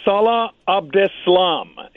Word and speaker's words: Salah Abdeslam: Yeah, Salah Abdeslam.